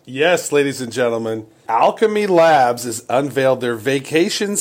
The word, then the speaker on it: Owlchemy: Talking of vacations, Travis, I noticed that a company called Alchemy, now they spell that O-W-L-C-H-E-M-Y, Alchemy.